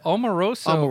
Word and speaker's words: Omarosa: Amorosa. Am I saying it right? – Well, Omarosa. Yeah, Omarosa. Yeah, she just.